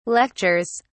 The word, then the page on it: lectures: British English